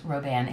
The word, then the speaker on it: Robin: It's Robin, R-O-B-I-N, but it's pronounced Roban. It's French.